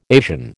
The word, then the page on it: Asian: American English